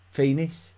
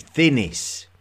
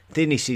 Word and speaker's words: Thinis: It's actually Thinis. Thinis is the lost capital city of um, of early Egypt. Uh, it's spelled T H I N I S, Thinis.